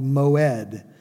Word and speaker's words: moed: Now, the appointed times in the New American Standard Bible, that two-word phrase is a single word called moed. It would be spelled like, "I just mowed my lawn," M-O-W-E-D.